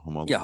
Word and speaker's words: Homolka: Bernardo and Homolka, right? Am I saying that right now? – Homolka.